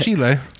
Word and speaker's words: Chile: Chile.